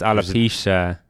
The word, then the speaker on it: alopecia: You're saying that wrong. Difference. It's alopecia.